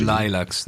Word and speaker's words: lilacs: Ok, in questo disco che ho tra le mani, che porta il titolo di Midnight Lilacs, si pronuncia così. – Lilacs, ecco, lo sapevo.